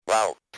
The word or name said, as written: about